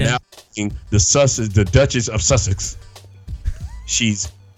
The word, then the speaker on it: Sussex: Sussex. – Oh, Sussex.